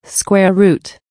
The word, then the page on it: square root: American English